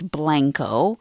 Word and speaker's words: Blanco: Blanco mm-hmm. isn't Blanco, it's Blanco.